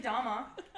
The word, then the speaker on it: Dahmer: Jeffrey Dahmer.